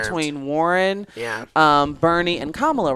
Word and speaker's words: Kamala: And that is how you pronounce her name. It is Kamala.